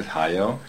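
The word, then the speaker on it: Hajoe: So the spelling is Hayo. It's H-A-J-O-E, but you pronounce it like Ohio, and except you're skipping the O. And the last name is Mordreger. That's M-O-D-E-R-E-G-G-E-R, and that's like the Schwarzenegger, the same origin of the word.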